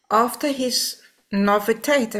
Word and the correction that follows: novitiate: am I saying it right? Francis? – Novitiate. – Novitiate. – Yeah, that's right.